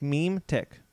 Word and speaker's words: Mimetic: Like meme tick.